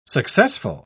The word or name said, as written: successful